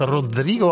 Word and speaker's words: Rodrigo: Olivia Rodrigo, First Play. Jetzt habe ich jetzt Rodrigo auch halbwegs gut ausgesprochen, oder? – Ja.